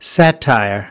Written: satire

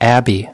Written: abbey

